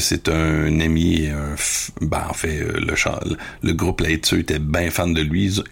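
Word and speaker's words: Lae Tseu: Peut-être plus, c'est la formation Laetseu. Donc, ça s'écrit L-A-E, espace T-S-E-U, Laetseu.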